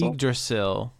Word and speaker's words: Yggdrasil: Let's see if I can pronounce it right. Frozen Yggdrasil? – I think Yggdrasil. It's Yggdrasil. – Yggdrasil.